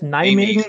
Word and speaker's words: Nijmegen: Nijmegen in, weiß gar nicht, ob ich das richtig ausgesprochen habe, der halbe Holländer sagt mir das gleich, Nijmegen.